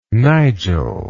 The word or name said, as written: Nigel